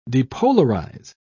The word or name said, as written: depolarize